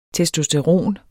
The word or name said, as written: testosteron